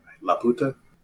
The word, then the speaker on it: Laputa: Laputa.